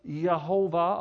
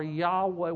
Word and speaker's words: Yahweh: but it's Jehovah or Yahweh. We pronounce it Jehovah or Jehovah, but it's really Yahweh Adonai.